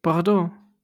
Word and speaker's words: Bordeaux: Bordeaux should not be spelled like that. Bordeaux, why? – Also, just, I love the way you said it. Bordeaux. – Bordeaux. That's how you Bordeaux. Say it. That is how you say it.